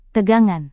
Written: tegangan

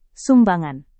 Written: sumbangan

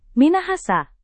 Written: Minahasa

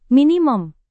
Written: minimum

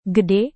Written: gede

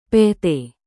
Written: pt

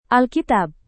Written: alkitab

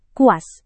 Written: kuas

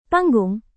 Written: panggung

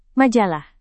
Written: majalah

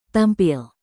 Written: tampil

